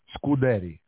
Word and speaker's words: Scuderi: Can you pronounce your last name? Because I feel like I said it wrong. – Scuderi. – Scuderi. – Yeah, yeah.